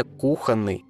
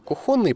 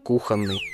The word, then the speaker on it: кухонный: Но как бы там ни было, единственно правильное ударение – кухонный. Некоторые считают, что вариант кухонный появляется под влиянием украинского и белорусского языков. В них это слово произносится с ударением на втором слоге, а у нас кухонный.